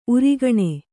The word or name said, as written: ಊರಗುಣಿ